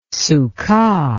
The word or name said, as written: succah